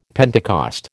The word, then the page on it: Pentecost: American English